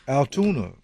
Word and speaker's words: Altoona: Altoona. – What? – That's how you say it.